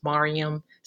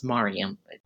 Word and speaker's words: Miriam: The first name is pronounced Mariam, spelled like Miriam, but pronounced Mariam.